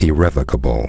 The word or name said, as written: irrevocable